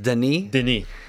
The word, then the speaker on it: Denis: I did learn that his first name is actually pronounced Denis.